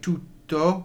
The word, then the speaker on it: tutto: Again, keep an eye out for double consonants, like tutto, where you're trying to pronounce both T's, and the use of C's.